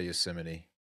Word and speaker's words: Yosemite: Some people pronounce it Yosemite, but I say Yosemite.